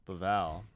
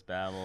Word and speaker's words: Bavel: Yeah, it's like my last name is actually pronounced Bavel, but everyone says Babel or.